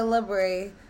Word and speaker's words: La Brea: They call it La Brea, but it's spelled like La Brea.